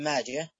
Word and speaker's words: ماجه: طبعا كتاب طبعا ابن ماجه اللي هو محمد بن يزيد القزويني الإمام الحافظ الكبير، وطبعا ماجه تنطق هكذا على الصحيح بدون التاء ابن ماجه،